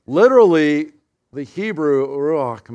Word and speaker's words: Ruach: Ruach. Okay, together. Ruach.